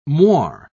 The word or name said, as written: moire